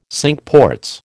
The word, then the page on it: Cinque Ports: American English